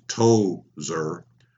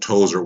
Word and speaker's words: Towser: Emphasis would have applied to the name's first syllable. It seems Towser had the strength of a micro Clydesdale and was employed to haul my roller-skated dad at high speed around his Clontarf home.